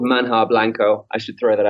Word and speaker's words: manjar: Manja is spelt with a J in the middle, so it's spelt manjar, but of course in Spanish it's pronounced manja.